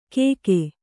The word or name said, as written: ಕೇಕೇ